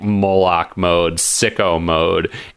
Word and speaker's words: Moloch: Moloch. You have been mispronouncing his name. Moloch. Moloch. – Moloch. – Moloch. – It is Moloch. Patrick is right.